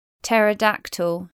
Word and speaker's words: pterodactyl: PT spelling from Greek is silent P. Pterodactyl, ptarmigan.